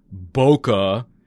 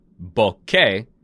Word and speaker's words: Bokeh: whether or not you say K or ke, it is two distinct syllables. Boca is not a photography term. Bokeh is a photography term.